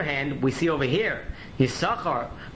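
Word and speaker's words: Yissachar: Although the Chassam Sofer says that the first time in the Torah that the name Yisachar is mentioned, you should pronounce it Yisachar. But typically, it is pronounced Yisachar.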